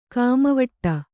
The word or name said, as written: ಕಾಮವಟ್ಟ